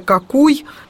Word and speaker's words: Какуй: Ну, и второе русло – это Какуй. Вот, ну, просто вообще, ну, пишется через «а».